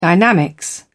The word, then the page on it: dynamics: British English